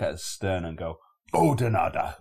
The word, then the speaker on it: Oudenaarde: Now, to an English speaking person, that just looks like Oudenaarde or Oudenaarde, right? And we were going, where's Oudenaarde? And the people we got told off because it's pronounced Oudenaarde. You don't pronounce the U, so it's like a Oudenaarde.